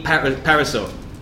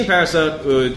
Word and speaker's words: Parisot: Anyway, Dean Parisot, let's call him Parisot, because I think it sounds better if the T is silent, is the director of Fun with Dick and Jane, but he's also the director of Galaxy Quest.